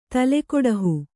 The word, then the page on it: ತಲೆಗೊಡಹು: kannada